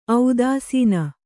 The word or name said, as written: ಔದಾಸೀನ